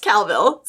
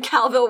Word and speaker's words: Colville: Sorry, it just told me how it's pronounced. Pronounced Calville. Sorry, I'll go back. However, when going to high school in Calville, Washington, I did have a friend who saw cryptid once.